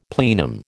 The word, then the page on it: plenum: American English